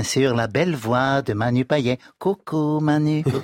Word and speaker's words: Payet: Mais il faut dire Manu Payet. – Oui, Payet. En français, on prononce tout le temps. Non, monsieur, c'est Payet. À la Réunion, on dit Manu Payet.